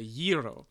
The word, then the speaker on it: gyro: That's, I believe in the Greek they pronounce that a gyro. – Gyro, of course.